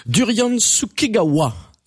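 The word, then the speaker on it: Durian Sukegawa: Philippe, Durian Tsukigawa, est-ce que je le dis bien? – Je pense que oui.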